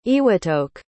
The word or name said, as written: Iwatoke